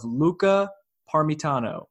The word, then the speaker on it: Luca Parmitano: This is a DJ set in space by an Italian astronaut by the name of Luca Parmitano.